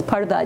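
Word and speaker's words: parda (پردہ): It's like the word people use in English, P-U-R-D-A-H, which they pronounce purda sometimes, but it's parda, it means being in a curtain or being behind a curtain.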